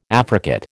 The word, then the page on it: affricate: American English